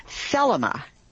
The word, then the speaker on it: Thelema: Well, we got a follow-up email, and what she was asking about is something called Thelema. T-H-E-L-E-M-A was the emphasis on the first syllable.